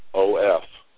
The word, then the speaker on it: of: Because every time I read the word of, O-F, I saw a V, not an F. Because in English, we pronounce of like a V.